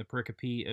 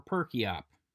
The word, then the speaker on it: pericope: And it is not, as I have pronounced it, pericope or perkiop. Pericope.